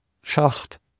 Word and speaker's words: Schacht: Just, for example, search for data science Schacht, and then it should come up.